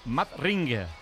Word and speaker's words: Madring: Pero, ¿cómo se dice Madrid? Madrid. – Madrid. Teóricamente, Madrid. Madring.